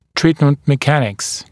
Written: treatment mechanics

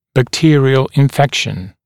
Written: bacterial infection

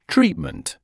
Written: treatment

